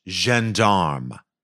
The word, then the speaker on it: gendarme: He's a French gendarme. Okay. Am I saying that right? – I believe you are, yes.